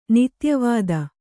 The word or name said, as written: ನಿತ್ಯವಿಧಿ